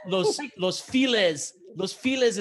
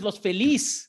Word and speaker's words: Los Feliz: Los files is not los files putos. Los files is los feliz.